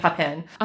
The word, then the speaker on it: Papin: It is under true crime because it is based on a true story of the Papin sisters. Hopefully I'm pronouncing that right. Leia and Christine Papin.